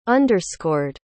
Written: underscored

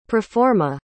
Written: proforma

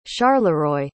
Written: charleroi

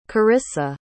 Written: karissa

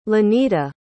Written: lanita

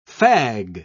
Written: fag

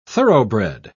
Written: thoroughbred

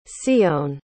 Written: sionn

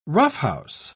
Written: roughhouse

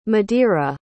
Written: Madeira

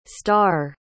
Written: starr

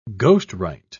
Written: ghostwrite

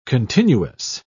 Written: continuous

